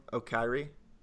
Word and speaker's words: Okoye: Okoye.